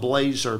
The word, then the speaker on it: Blazar: But my name is spelled Blazer, B-L-A-Z-A-R. There's no E's in the spelling of my first or last name.